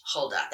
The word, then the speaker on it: Huldah: And her name is spelled H-U-L-D-A-H, Huldah. That's how you pronounce it. Her name is Huldah.